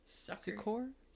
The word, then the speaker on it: Succor: So he moves to Sucor Creek, S-U-C-C-O-R, in about 1883. So, yeah, how would you say that? – S-U-C-C-O-R. – Sucor. – So it almost sounds like Sequoia.